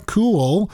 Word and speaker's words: Kuehl: Dateline Santa Monica, protesters gathered outside the home of LA County Supervisor Sheila Kuehl, if I'm pronouncing that correctly, K-U-E-H-L, Kuehl, on Tuesday after a Fox 11 report revealed hours after she voted to ban outdoor dining.